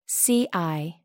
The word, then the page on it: ci: American English